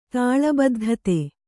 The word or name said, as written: ತಾಳಬದ್ಧತೆ